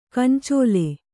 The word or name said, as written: ಗೊಂಚಲ್